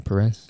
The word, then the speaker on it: Perez: Am I pronouncing that right, Perez? – Yeah.